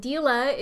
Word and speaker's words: Deela: Deela designs on everything and Deela is spelled d E E L A.